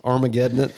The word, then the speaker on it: Armageddon It: Armageddon It.